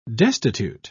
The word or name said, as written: destitute